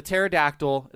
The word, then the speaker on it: pterodactyl: The pterodactyl, it's got the silent P.